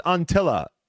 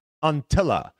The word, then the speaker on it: Anttila: All right, Nicholas Anttila. Hey, eh, apologies, I totally butchered his name. I, I said it the, incorrectly last week. Anttila. Um, Anttila.